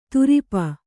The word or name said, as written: ತುರಿಪ